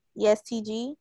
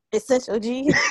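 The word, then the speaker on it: EST Gee: It's I can never say his name. ESTG. That's how you say it. – Yeah, that's that's how you pronounce it. you was about to say S. – Essential G.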